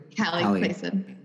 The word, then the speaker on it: Hallie Clason: Hallie Clason.